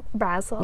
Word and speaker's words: Basil: Basil. – Oregano. – You're hilarious. – That's the Basil. Brits say. Basil.